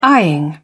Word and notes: eyeing: British English

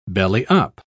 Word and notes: belly-up: British English

